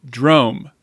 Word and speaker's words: Drom: It's called Drome. That's spelled D-R-O-M. It's a Swedish word.